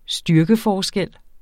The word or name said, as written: styrkeforskel